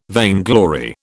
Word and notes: vainglory: British English

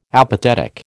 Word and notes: apathetic: American English

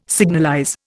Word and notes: signalize: Indian

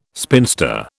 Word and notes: spinster: British English

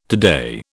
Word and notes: today: British English

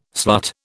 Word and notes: slut: British English

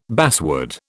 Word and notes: basswood: British English

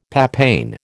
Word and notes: papain: American English

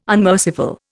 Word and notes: unmerciful: Indian

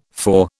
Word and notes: four: British English

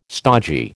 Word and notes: stogy: American English

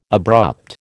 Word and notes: abrupt: American English

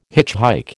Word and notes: hitchhike: American English

